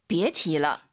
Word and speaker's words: 别提了: 别提了. – 이성경성. 아하. 이 음정 떠올리세요. 别提了 해도 알아듣지만 틀린 발음이에요. 다시.